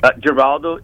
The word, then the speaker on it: Geraldo: Geraldo, G-E-R-A-L-D-O.